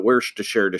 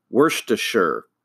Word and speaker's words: Worcestershire: I'll put a link to it if you're one of those people like me that have always called it Worcestershire sauce. But it's Worcestershire.